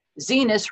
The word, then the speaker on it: Zenus: So Henry appears in an episode of Drunk History where he plays Zenus, rhymes with penis, Fisk Welber.